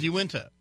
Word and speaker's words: Uintah: The Yun Yunta, it's spelled weirdly. – Oh, it's Uintah. – Yeah, Yunta.